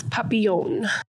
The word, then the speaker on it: Papillion: It's Papillion.